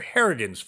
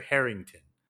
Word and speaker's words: Harrigan: So I have to correct myself. It's Mr. Harrigan's Phone. There's no T. I said it wrong. – I kept doing that too. – It's Mr. Harrigan's Phone, not Mr. Harrington.